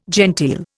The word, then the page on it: genteel: Indian